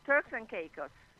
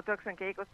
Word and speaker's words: Turks and Caicos: Turks and Caicos. È un'isola di lingua inglese. Dico Turks and Caicos perché loro sono di lingua inglese principalmente.